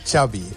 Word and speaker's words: Xavi: Xavi. – Ya, okay. Xavi like chocolate.